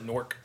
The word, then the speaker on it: Newark: I thought it was Newark, but yeah, they pronounce it Newark.